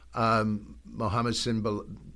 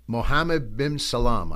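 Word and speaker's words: Mohammed bin Salman: MSM, who's the leader now of Saudi Arabia, Mohammed Sinbal, I mispronounced the name. Goes by MSM, so pardon me for that. Mohammed bin Salam, I believe that is. I'm sorry, it's going to bother me.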